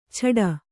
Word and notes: ಛಡ: chaḍa

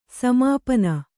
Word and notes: ಸಮಾಪನ: Kannada

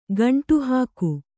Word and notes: ಗುಂಡುಹಾಕು: guṇḍu hāku

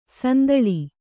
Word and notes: ಸಂದಲಿ: sandali

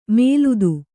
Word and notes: ಮೇಲುದು: mēludu

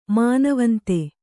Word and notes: ಮಾನವಂತೆ: mānavante

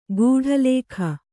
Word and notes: ಗೂಢಲೇಖ: gūḍha lēkha

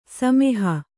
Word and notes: ಸಮೂಹ: kannada; samūha

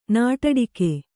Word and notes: ನಾಡಾಡಿಗ: nāḍāḍiga

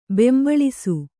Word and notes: ಬೊಂಬಾಳಿಸು: bombāḷisu

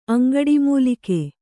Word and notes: ಅಂಗಡಿಮೂಲಿಕೆ: aŋgaḍimūlike

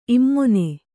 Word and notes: ಇಮಾನಿ: kannada

